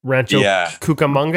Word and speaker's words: Rancho Cucamonga: Like Rancho Cucamonga.